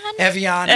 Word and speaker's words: Evian: Do you remember Avion? Have you seen Avion? – Evian.